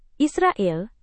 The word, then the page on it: israel: indonesian